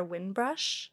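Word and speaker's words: Winbrush: I don't know if she has a brick and mortar store, but is Alexandra Windbrush. And they're really Windbrush? – Good. – Can you spell that? – WINBR USH. – So exactly what it sounds like.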